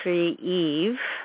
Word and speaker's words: Kyiv: And I'll try to say this with the Ukrainian pronunciation, which is Kyiv, Ukraine,